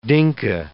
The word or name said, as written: Dinka